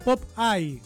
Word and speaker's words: Popeye: En inglés se pronuncia Popeye.